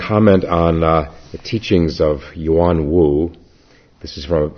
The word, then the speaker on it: Yuan Wu: I'll read just a little bit of biographical material about Yuan Wu. It's spelled Y-U-A-N, Yuan Wu, W-U.